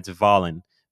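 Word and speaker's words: Wallin: If you look his name up, it's spelled with a W, but he's from one of those Nordic countries. So their W is a V. It's Valen.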